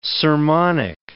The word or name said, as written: sermonic